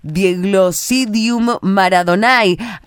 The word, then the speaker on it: Diegoglossidium Maradonai: Estamos con, mucha intriga, con, con muchas ganas de, conocer la historia de Dieglosidium Maradonae. ¿Lo dije bien o se pronuncia de otra manera? – medio trabado, pero estuvo perfecto.